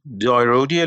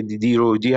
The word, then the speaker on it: DiroDi: یه مشتری زنگ زد گفت دایرودی الکتریک باکس مثلا نمیدونستم مثلا دیرودی دایرودی هم میتونه تلفظ بشه یکی دیگه زنگ زد گفت دیرو دای الکتریک باکس بعد فهمیدم که اونجوری توی مثل ایران نیست خلاصه که دیرودی همیشه دیرودی خونده بشه